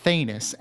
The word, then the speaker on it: Thanos: Like, the people make this movie think they're comic book fans, but they don't even pronounce his name right. Thanos.